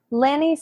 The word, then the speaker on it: Lanny: That's Lanny Serim for a few years now. It's Lanny, or as she would say, Annie with an L, just in case you were also wondering.